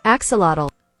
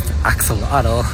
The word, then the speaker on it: axolotl: Well, if you watch any English-speaking videos, they're going to tell you that it's pronounced axolotl. And that is so incorrectly mispronounced. And it is correctly pronounced axolot. – Axolot. – Axolot. That's how you say that word.